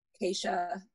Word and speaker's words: Keisha: It's like Keisha, like Geisha with a K.